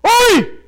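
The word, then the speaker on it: oi (הוֹי): Imagine right there the word H-O. With an exclamation mark. And it's not actually pronounced ho, it's actually pronounced oi!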